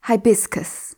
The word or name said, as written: hibiscus